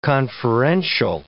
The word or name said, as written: conferential